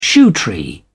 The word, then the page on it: shoetree: British English